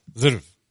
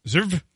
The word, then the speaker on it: Zverev: Zverev. – Zverev.